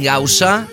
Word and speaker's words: Gausa: arroba David Gausa, G-A-U-S-A.